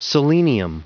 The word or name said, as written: selenium